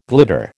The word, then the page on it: glitter: American English